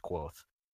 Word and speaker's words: Quoth: Yeah, it's spelt like Kavoth, but he tells you straight up it's pronounced Quoth.